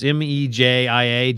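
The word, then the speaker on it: Mejía: Well, and another interesting baseball item that happened yesterday is Jerry Mejia, I think that's how you pronounce his name, right? The relief pitcher he has been banned. Yeah, it's Mejia, Jerry Mejia.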